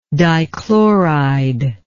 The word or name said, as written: dichloride